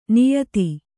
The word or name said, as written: ನಿಯತಿ